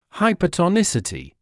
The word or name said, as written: hypertonicity